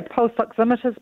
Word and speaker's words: oximeter: Am I pronouncing it right, oximeter? – Yeah, pulse oximeters, pulse oximeters, depending on your pronunciation.